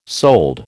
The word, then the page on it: sold: American English